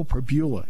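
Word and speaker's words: Pribula: Just like Bo Prabula. That's how you pronounce his name. I went up to him yesterday, because I've talked to him before. I said, hey, Bo, I just want to make sure it's Bo Prabula.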